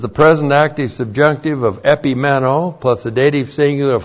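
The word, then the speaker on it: Epimeno (ἐπιμένω): E-P-I-M-E-N, long O. Epimeno.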